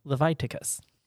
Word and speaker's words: Leviticus: How do you pronounce it? – Leviticus. – Leviticus. – Yeah, it sounds like a disease, but really that's not inappropriate given that diseases are talked about so frequently in the book of Leviticus.